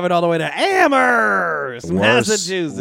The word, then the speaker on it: Amherst: We know it's pronounced Amherst. – Yeah. – No, you don't put the H in there.